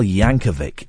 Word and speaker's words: Yankovic: And also next week, Weird Al Yankovic. It's a hard C at the end.